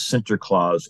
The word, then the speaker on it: Sinterklaas: The Dutch brought over their St. Nicholas traditions, but the Dutch pronounced St. Nicholas Sinterklaas or Santa Claus. So Santa Claus is the Dutch pronunciation of St. Nicholas.